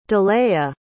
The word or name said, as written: delaiah